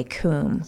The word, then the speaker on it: Cwm: It goes through the treacherous Khumbu Icefall and Western Kum, which is spelled C-W-M, but you say Kum. – C-W-M.